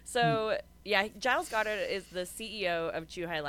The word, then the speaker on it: Giles Goddard: And the pronunciation is Giles Goddard. Is that how you say his name? Yeah, Giles Goddard.